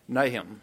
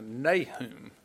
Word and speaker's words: Nahum: While you turn, it's an interesting, if you've heard me preach from this book in the past, you probably heard me pronounce it Nahum. And that's the way I learned to pronounce it growing up, was we learned the books of the Bible, you know, Jonah, Micah, Nahum, Habakkuk. Well, I heard recently a preacher preach from this, and he called it Nahum. And so I looked it up, and I've been pronouncing it wrong all these years,